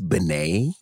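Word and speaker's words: benne: It's B-E-N-N-E is the way they're spelled, but yeah. – It's pronounced like B-E-N-N-Y.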